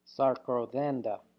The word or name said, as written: sarkwthenda